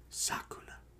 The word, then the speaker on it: Sukuna: Sakuna. That's how they say it.